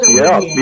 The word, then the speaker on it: Olivier: The salad is Olivier. I assume that's the way the Russian aristocrats would have pronounced it. – That is absolutely correct, and also very delicious. Salad Olivier.